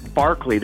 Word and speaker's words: Berkeley: Berkeley. They pronounce it Berkeley, but it's spelled the same.